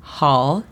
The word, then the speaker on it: hall: Hall significa pasillo. Y es la misma pronunciación que normalmente utilizamos para las pastillas halls, excepto sin la S. Hall. – Hall. – La pronunciación fonética es J-A-H-L.